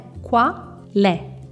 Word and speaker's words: qual è: Cuidado com a pronúncia de qual è, qual é? qua, le.